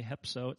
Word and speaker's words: hepso: Okay, hepso. It's spelled just like it sounds, H-E-P-S-O.